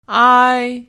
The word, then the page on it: ai: pinyin; tone 1